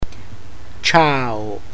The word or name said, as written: ciao